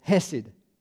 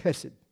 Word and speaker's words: hesed: So add another word to it, hesed, or if you got a cold, you can say it like hesed. But get H-E-S-E-D.